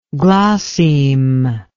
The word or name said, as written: glosseme